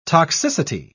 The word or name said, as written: toxicity